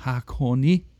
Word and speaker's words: Hakone: So, Hakone.